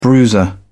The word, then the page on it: bruiser: British English